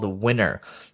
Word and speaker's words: Winnr: called Winner, and that's spelled W-I-N-N-R. So it's basically Winner without the E.